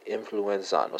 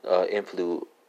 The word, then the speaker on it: influenciar: Because I think I might not be giving due pronunciation to that I at the end of the stem. So I-N-F-L-U-E-N-C-I-A-R, right? So there's an I at the end. Might need to put more emphasis on it, right?